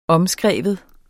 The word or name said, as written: omskrive